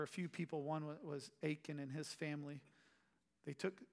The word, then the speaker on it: Achan: You have uh, Achan, A C H A N. Is that right? How you would pronounce it? Anybody? Achan.